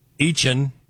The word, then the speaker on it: Etienne: And in the running back department, Etienne, who used to be Etienne.